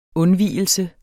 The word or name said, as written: undvigelse